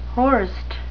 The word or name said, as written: horst